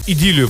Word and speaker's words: идиллию: Ну, вот эту всю летнюю нашу идиллию, или идиллию, правильно говорить, да? – Идиллию.